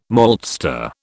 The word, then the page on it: maltster: British English